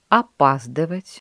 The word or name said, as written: опаздывать